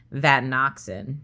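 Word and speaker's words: vatinoxan: Just an aside there, I asked my cousins how to pronounce this drug, because I had never heard of this drug, and you actually pronounced it the way they said to pronounce it, vadenoxin.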